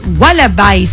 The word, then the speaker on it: Wallabies: El seleccionado argentino cayó frente a los Wallabies, ¿sería? ¿Se pronuncia así? Sí, me dice Pli.